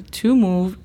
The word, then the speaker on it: move: Entonces, la pronunciación fonética de move es simplemente M-U-V y para decir to move es T-U-M-U-V.